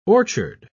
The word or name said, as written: orchard